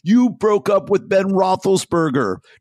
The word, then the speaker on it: Roethlisberger: And Trump calls Natalie Gulbis and says, I read in page 6 that that Ben Roethlisberger, is that pronunciation correct, Ryan? – Yeah, sure, for Trump, yeah. – Yeah. – That Ben Roethlisberger, that's funny, that Ben Roethlisberger broke up with you.